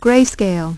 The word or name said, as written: gray-scale